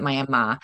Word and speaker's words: Miami: It's spelled like Miami, Florida, but we pronounce it Miami.